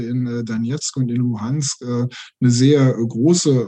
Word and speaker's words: Luhansk: Aber auf 2014, also auf die Annexion der Krim, folgte dann ja der Separatismus weiter im Osten, also im Donbass und Lugansk heißt die Stadt, wenn ich sie richtig ausspreche? – Ja, sie heißt auf Ukrainisch Luhansk.